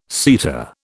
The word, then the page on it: seta: British English